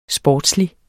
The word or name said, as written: sportslig